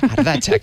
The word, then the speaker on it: Ardatzak: Bueno, pues lo decíamos al principio, este trabajo creo que en euskera, Inés, corrígeme si lo digo mal, es algo así como Ardatzak. Que significa... – Correcto, lo has dicho perfecto. – Ah, lo he dicho bien, mira. Ardatzak, que significa ejes en español.